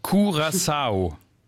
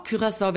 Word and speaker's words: Curaçao: Also richtige Aussprache, Curaçao. – Wenn man es wie die Inselbewohner machen möchte, genau. Curaçao wäre die niederländische Variante, habe ich aber auch oft dort gehört, geht beides.